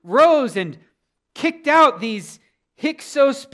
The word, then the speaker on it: Hyksos: I believe last week I was mispronouncing that. I think I was saying Hyksos, but it's hard to say. Hyksos.